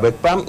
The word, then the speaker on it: Bokpam: Iniciamos precisamente hablando acerca del cráneo, que en lengua yucatán se pronuncia Bokpam, y ahora le toca el turno a los dientes.